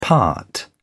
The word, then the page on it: part: British English